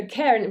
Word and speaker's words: Cerne: Yeah, so Cairn, which is spelled C-E-R-N-E, is derived from the Irish word cairn, which means stacked or piled rocks.